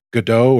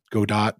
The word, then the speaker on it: Godot: And there's two projects in there, one called Intrepid and another one that's using Godot or Godot, depending on how you want to pronounce it.